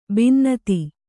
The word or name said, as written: ಭಿನ್ನತೆ